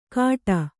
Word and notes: ಕಾಟ: kannada

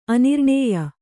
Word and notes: ಆನೃಣ್ಯ: Kannada

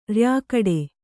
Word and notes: ರ‍್ಯಾಗಟೆ: ryāgaṭe

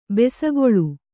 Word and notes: ಬೆಸಗೊಳ್ಳು: besagoḷḷu; kannada

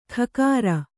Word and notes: ಖಕಾರ: khakāra

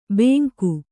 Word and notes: ಬೇಂಕು: Kannada

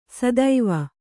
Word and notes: ಸದುವು: kannada

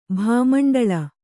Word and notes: ಭಾಮಂಡಲ: bhāmaṇḍala

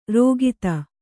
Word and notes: ರೋಗಿತ: rōgita

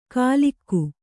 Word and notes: ಕಾಲಿಕ್ಕು: Kannada; kālikku

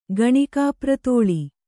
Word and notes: ಗಣಿಕಾಪ್ರತೋಳಿ: Kannada

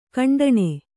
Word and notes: ಖಂಡಾನ: khaṇḍa

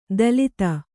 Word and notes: ದಲಿತ: dalita; Kannada

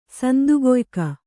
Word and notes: ಸಂದುಗೊಯ್ಕ: kannada

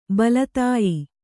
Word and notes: ಬಲತಾಯಿ: bala tāyi

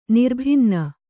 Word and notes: ನಿರ್ಭಿನ್ನೆ: nirbhinne

